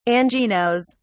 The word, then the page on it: anginose: British English